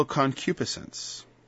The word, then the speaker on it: concupiscence: Evil concupiscence. Right? It took me a long time to learn to pronounce that properly. Concupiscence. Notice the cupid in there? That's like Cupid.